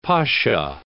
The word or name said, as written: pasha